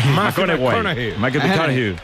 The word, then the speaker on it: McConaughey: ¿Mateo McConaughey? – Sí, sí, sí. Sí, sí. – Que hay que ir corrigiendo la pronunciación. – McConaughey.